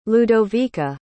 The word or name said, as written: ludovica